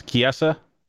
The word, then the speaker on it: Chiesa: I think they've also talked about having him fight Mike Chizia. I'm saying his name wrong, but you know who I'm referring to. – Yes. Chiesa? – Wow, is that how you say it? – Yes.